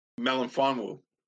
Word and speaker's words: Melifonwu: I can't even pronounce his last name. Melifonwu. there you go.